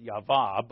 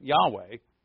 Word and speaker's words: Yahweh: Or I like to pronounce it Yahvah, but Yahweh.